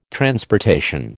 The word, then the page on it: transportation: American English